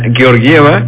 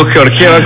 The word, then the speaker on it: Georgieva: Lo había adelantado Kristalina Georgieva, gracias a ti ahora lo pronuncio bien, y no Georgieva como antes lo decía, porque pues la bajaron 0.1%.